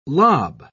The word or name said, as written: lob